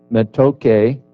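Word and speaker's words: metochē: I think I mispronounced it as patoke, patoke. Let me look at it again. I don't want to be misquoting this. Metoke, M-E-T-O-C-H-E.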